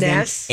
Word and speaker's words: Anees: Aness, I believe, is how you say Aness. – His name. A-N-E-E-S, Sun and Moon is the song.